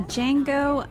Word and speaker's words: Django: Yeah, Django Unchained. The D is silent.